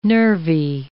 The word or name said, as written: nervy